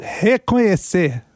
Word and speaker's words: reconhece: I know the third syllable is a tough one. Reconhe. Try to get the nye. – Reconhe? – Yes. Reconhece. – Reconhece. – But se. – Oh, that's tricky. – Se is the stress syllable. Reconhece. Reconhece. – Reconhece. – Yes. Sir, okay. The last syllable is a stress one, so reconhece.